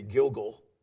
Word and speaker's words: Gilgal: And really, pronunciation should be Gilgal. There is a play on words here in the Hebrew that makes it sound like circle.